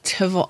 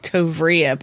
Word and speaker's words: Tovrea: It's spelled Jean Tavari. I think it's Tavari. That's how most people pronounce it. T-O-V-R-E-A. It looks like Tovria, but I heard Tavari.